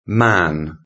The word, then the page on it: man: British English